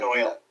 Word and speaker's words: Noyle: I am here with not Alex Noyle, or do you pronounce Noyle? – Like, Noyle. – Then okay, so it sounds like Boyle, but with Noyle.